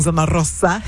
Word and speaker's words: Zona Rosa: Right now, out of Zona Rosa, you got to say it like that and roll your tongue, Zona Rosa.